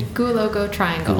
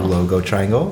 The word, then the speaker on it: Goualogo Triangle: Can you pronounce it for me? – Goualogo Triangle. – Goualogo Triangle. – That's right.